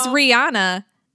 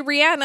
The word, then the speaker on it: Rihanna: Is that how you That's how it's pronounced. Everybody says Rihanna, and it's actually Rihanna, and I try to respect that, but I'm not a jerk, and I don't correct everybody else.